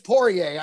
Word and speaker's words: Poirier: Poirier. I'm pretty sure it's Poirier. I mean, that's how they say it on all the UFC programs. – All right, Poirier.